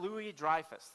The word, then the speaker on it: Louis-Dreyfus: Jerry Seinfeld had Louis Ray, I gotta remember how to say her name, I always say it wrong. Or Julia Louis-Dreyfus, there you go.